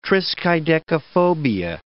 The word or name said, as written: triskaidekaphobia